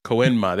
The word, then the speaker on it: Koenma: Kyunma, I forget how to say her name. His name. – Koenma. – Pinky boy. Koenma, there we go.